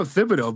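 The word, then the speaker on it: Thibodeaux: With your pronunciation, do you go with the long th or do you go with the Tom Thibodeau with the hard t for Kayvon? – I go Thibodeau, but if I'm wrong, I'm wrong.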